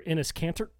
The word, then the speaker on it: Enes Kanter: But on October 26th, something like that, Boston Celtics star Enes Kanter. Am I saying that right, Cody? – Yeah, sounds right.